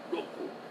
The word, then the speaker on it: Roku: You don't roll your R's in Japanese. It's Roku. Roku. In Russia, you roll your R's.